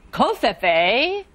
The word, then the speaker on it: covfefe: Are there any alternate pronunciations? – Yes. Oh, many, many. Kofifi, kofefe, kofef. – Stop stalling. Do you know how to spell this word? – C-O-F-E, kofefe, C-O-F-E-F-E.